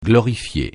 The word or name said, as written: glorifier